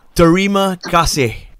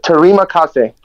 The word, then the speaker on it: Terima kasih: Terima kasih. – Terima kasih.